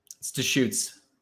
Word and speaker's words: Deschutes: It's Deschutes.